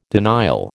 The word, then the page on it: denial: American English